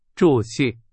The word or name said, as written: 着